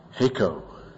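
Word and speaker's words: heko (ἥκω): And the verb, heko, H-E-K, long O, heko.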